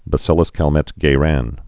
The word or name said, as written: Bacillus Calmette-Guerin vaccine